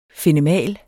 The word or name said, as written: fenemal